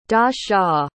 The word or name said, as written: da-xia